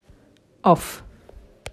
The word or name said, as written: off